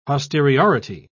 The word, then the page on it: posteriority: British English